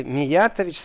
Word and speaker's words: Миятович: Э, я понимаю, что это неправильно сейчас было сказано югославский, но мне иногда настолько все равно вот эта вот толерантность, так что если Миятович, спасибо, буду знать, что э, на втором слоге, не на третьем, даже не на четвертом, не на первом, но это настолько все равно с точки зрения, где стоит ударение, к самому факту.